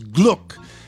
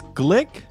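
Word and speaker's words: Glück: So a lot of these things are about Louise, and I thought her last name was Gluck because of the umlaut. I thought it was either Gluck or Gluck. You say it's pronounced... – Apparently it's Glick.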